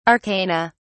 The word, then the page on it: arcana: British English